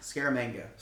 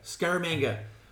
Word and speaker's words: Scaramanga: Um, again, I, I think I maybe hold more emphasis on Scarmanger, uh, Scarmanga. How do you say it? Scaramanga. Scaramanga.